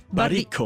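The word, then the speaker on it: Baricco: Barico.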